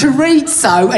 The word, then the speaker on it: chorizo: It's chorizo and it's paella, so I fucking get your pronunciation right, you bell end.